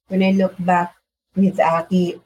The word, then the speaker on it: Aki: How do you say Addy? – Yeah. Aki. A-K-I. A-K-I.